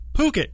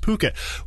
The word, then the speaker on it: Phuket: Or Phuket in Thailand. It does have the PH at the beginning. Don't let that fool you. It is pronounced Phuket.